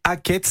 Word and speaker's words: Aket: Oui, elle est signée quête, ça s'écrit AKET.